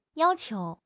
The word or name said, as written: yao1 qiu2